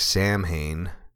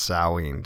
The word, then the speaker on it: Samhain: Samhain, what looks like it's spelled like Samhain, but it's pronounced Samhain. Don't ask me why.